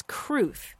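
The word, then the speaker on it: crwth: Well, yeah, and the other word that you're thinking of is Kruth. And I haven't seen, Grant, have you seen it spelled with a K? I've seen it spelled with a C, C-R-W-T-H, mm-mm.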